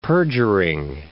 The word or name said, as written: perjuring